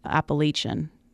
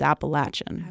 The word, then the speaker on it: Appalachian: Although actually, they would actually pronounce it Appalachian, which is wrong. It's Appalachian.